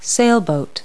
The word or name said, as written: sailboat